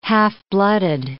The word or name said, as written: half-blooded